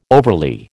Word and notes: overly: American English

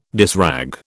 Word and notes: dishrag: British English

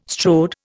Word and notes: strode: Indian

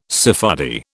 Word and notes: Sephardi: British English

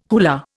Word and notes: hula: Indian

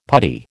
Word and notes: putty: American English

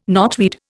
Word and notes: knotweed: Indian

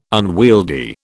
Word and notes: unwieldy: British English

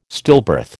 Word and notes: stillbirth: American English

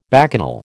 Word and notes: bacchanal: American English

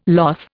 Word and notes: loth: Indian

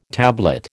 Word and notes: tablet: American English

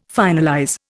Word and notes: finalize: Indian

